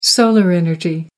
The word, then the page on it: solar energy: American English